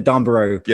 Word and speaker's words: Darnborough: I can't even pronounce my own name correctly now because I have to pronounce it in America so often that I just go with the Darnborough.